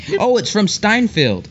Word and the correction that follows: Seinfeld: Or Seinfeld.